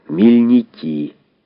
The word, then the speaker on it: Мельники: И хутор их так и назывался «Мельники» с ударением на конце.